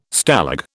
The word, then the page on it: Stalag: British English